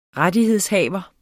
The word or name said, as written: rettighedshaver